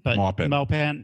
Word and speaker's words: Maupin: Maupin, am I saying it right or am I still saying it wrong? – Maupin. You say you and I'll say me.